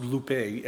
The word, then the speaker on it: lupe: That Greek word there for sorrow is Strong's 3077, approximately pronounced lupe, L-U-P-E, and it means grief or heaviness, to be consumed with it, being drowned in discouragement and despair.